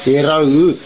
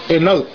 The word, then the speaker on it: Eraut: Okay, Pete, let me try to pronounce it the right way in Tobian. Eraut. That's the right pronunciation of it's going to rain in translation. But normally kids nowadays are using the short way of saying it, which is enot, and that's not the right way of saying that word.